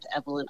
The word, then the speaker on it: Evelyn: I just joined a few weeks ago, but you can find me on Twitter too at Evelyn Alsultany. – Very good. Evelyn Alsultany. And I'll spell that. Of course, it's Evelyn, E-V-E-L-Y-N. And Alsultany is spelled A-L-S-U-L-T-A-N-Y. Correct? – Correct.